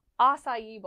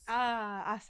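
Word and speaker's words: açaí: So for the first one, we're going to talk about acai bowls. – Acai bowls. – And that is actually how it's pronounced. – Yes, not acai.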